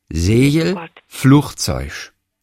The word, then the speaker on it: Segelflugzeug: Ein Wort ist hier genannt, in dem gibt es drei Gs und jedes G wird anders ausgesprochen und es ist das Wort Segelflugzeug. Segelflugzeug. Da drehst du doch durch. Ich finde das so herrlich. Segelflugzeug. Das erste Segel wird wie ein J ausgesprochen, das Flug wie CH und hinten das Zeug wie ein Sch.